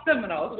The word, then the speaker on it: Seminole: So it's spelled S-E-M-I-N-O-L-E. Seminole, Some People Never Give Up.